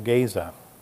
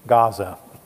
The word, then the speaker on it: Gaza: Let's take a look at Philip's ministry now going down into Gaza, or as we pronounce it today, Gaza.